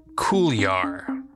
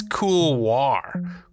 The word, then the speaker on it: couloir: Talking with Danny there, on our little tangent, getting a little off topic, was the word couloir. Did I say it right? I don't know. I think it's couloir. Couloir. C-O-U-L-O-I-R. Yeah.